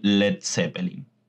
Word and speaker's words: Led Zeppelin: se suprimió la A de LEAD para evitar problemas de pronunciación por parte de los hablantes norteamericanos, ya que las vocales E y A se pronuncian como una I en inglés de Norteamérica, es por eso que el nombre quedó en Led Zeppelin.